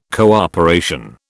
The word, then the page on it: cooperation: British English